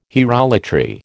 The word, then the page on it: hierolatry: American English